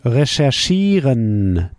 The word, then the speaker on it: recherchieren: Recherchieren is to research. Recherchieren, to research. How would you say, I research? Ich recherchiere. You see, we pronounce it a little bit French style.